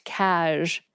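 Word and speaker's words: cazh: Stan Carey tweeted in 2015, quote, "Lots of variation and uncertainty over the spelling of abbreviated casual, pronounced cash. C-A-Z-H, C-A-J, C-A-S, C-A-S-J, C-A-S-Z, etc.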